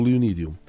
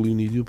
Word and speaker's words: Leonídio: Epá, eh, se calhar começo por dizer que sou o Leonídio. – Sim. Eu, tenho eu disse aqui... mal o nome? Pronunciei mal? Não, pronunciaste bem. – Pronunciaste bem. Um, eu digo que sou o Leonídio porque sou daquelas pessoas que têm um nome tão pouco comum que muitas vezes as pessoas tratam já só por Leonídio.